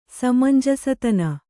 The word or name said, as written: ಸಮಂಜಸತನ